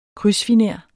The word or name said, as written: KRYDSFINER